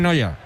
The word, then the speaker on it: Neuer: Yo digo, ese se pronuncia, es que es Neuer. Pero se pronuncia no. casi igual, sí, sí. – Sí, sí, se pronuncia en Noya.